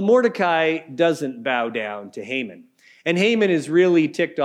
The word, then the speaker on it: Haman: A guy named Haman, or perhaps Haman might be a better way to pronounce it.